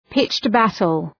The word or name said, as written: pitched battle